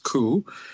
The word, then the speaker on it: coup: Now, I know it's coup like soup, but that's not what it means. It's pronounced coup.